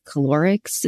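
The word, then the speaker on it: choleric: And then calorics. Is that how you say it? If you've been listening. – Like cholera.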